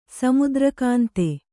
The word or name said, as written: ಸಮುದ್ರಕಾಂತೆ